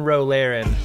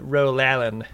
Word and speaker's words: Ro Laren: And with one Ro Laren, as Picard pronounces it, Ro Laren.